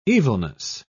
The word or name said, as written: evilness